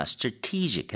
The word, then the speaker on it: strategic: 참고로 strategic이 아니라 strategic 해서 te에 강세를 주고 발음을 하시는 게 맞겠죠.